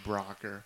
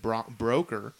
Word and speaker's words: Brokkr: Yeah, a very fitting name there, Brocker. When you take a look at it, I first pronounced it Broker, although it definitely makes sense since it's spelled B-R-O-K-K-R.